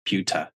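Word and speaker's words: Pyyhtiä: His last name is spelled P-Y-Y-H-T-I-A, and somehow you get Puta out of that.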